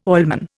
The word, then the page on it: oilman: Indian